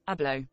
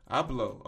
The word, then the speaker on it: Abloh: Uh, say say it with me. Abloh. Abloh. Oh, Virgil Abloh. Okay, prolific fashion designer Virgil Abloh dies at 41.